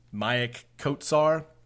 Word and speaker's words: Maik Kotsar: And they have a big dude from Estonia, six foot eleven, Maik Kotsar I believe is how you say his name.